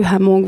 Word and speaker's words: Hmong: Le Hmong, oui, c'est vrai qu'il faut prononcer ouais, hein. Le H.